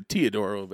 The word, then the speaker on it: Teodoro: Like, look at Cody Webster and Bo Sheets and Lucas Toledo, you know, fighting bull. Did I say that right? – Yeah, I mean, Teodoro, but yeah.